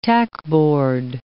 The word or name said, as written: tackboard